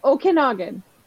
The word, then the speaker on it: Okanagan: How do you pronounce Okanagan? What? – Okanagan.